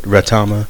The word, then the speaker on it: Retama: That's how you say it, Retama.